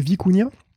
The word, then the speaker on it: Vicuña: Donc, en tout cas, c'est la prononce donc de Vicuña, donc c'est écrit V-I-C-U-N-A.